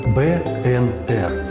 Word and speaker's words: БНР: Вимовляємо абревіатуру правильно, літера за літерой – БНР.